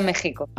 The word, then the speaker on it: México: se escribe con X. Pues se pronuncia México.